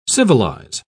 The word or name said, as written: civilize